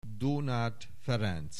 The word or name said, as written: Donáth Ferenc